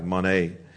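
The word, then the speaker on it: mane (μονή): In the Greek, mane, pronounce it M-O-N-A-Y with an accent on the A-Y, mane.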